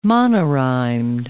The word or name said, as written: monorhymed